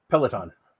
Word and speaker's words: Peloton: Peloton.